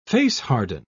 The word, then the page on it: face-harden: British English